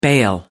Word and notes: bail: American English

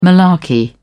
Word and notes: malarkey: British English

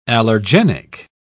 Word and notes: allergenic: British English

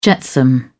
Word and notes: jetsam: American English

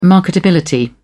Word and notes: marketability: British English

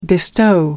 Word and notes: bestow: American English

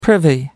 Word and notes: privy: American English

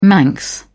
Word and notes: manx: British English

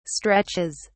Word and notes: stretches: British English

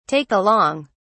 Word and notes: take along: British English

